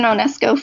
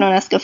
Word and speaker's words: Onysko: Yeah, uh, you know, Susan Onysko Photo, Susan Onysko Photography, anything will get you there.